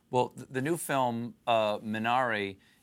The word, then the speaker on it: Minari: Minari, and pronounce how Koreans would pronounce it, Minari.